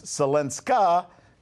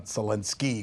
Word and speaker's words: Zelenska: According to Kiev, officially now, Mrs. Zelenska, yes, that's how you pronounce her name. It's not Zelensky because she's the wife, female, whatever.